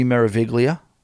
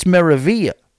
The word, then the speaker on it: Meraviglia: All right, the G is silent. No. We're taking it a step further. We're putting the silence on the L, too. It's Meraviglia.